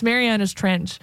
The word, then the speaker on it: Marianas Trench: I know Matt's wanting you to guess because apparently you've been pronouncing their name wrong on occasion. It's Marianas Trench.